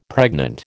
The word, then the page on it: pregnant: American English